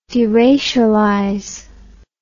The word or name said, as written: deracialize